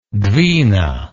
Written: dvina

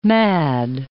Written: mad

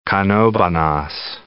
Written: Canovanas

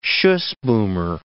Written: schuss boomer

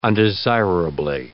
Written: undesirably

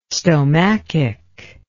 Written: stomachic

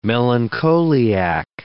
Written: melancholiac

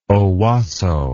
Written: Owosso

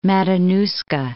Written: Matanuska

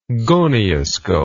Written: gonioscope

